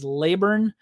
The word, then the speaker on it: Laburn: His last name looks like Laybourne or Laborn or however you want to say it, but I believe that I saw that the pronunciation is Laburn. So I hope that that's right.